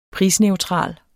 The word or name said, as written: prisneutral